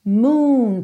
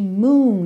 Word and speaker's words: moon: Moon. Percebe que esse som é compridinho, ok? Moon.